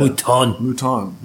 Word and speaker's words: Mouton: Have you guys seen, I hope I pronounce her name right, Michelle Mouton. – Mouton, yeah. – Mouton.